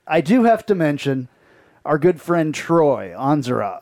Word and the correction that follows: Andrzak: I can never say his name. His last name. – Andrzak. – Andrzak, yeah.